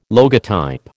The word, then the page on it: logotype: American English